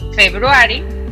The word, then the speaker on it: February: Cuidado con los acentos donde ponemos la palabra, porque queremos decir February, y no es February.